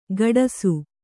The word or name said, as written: ಕಡಾಸು